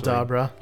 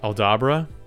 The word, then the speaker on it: Aldabra: Aldabra. – Aldabra.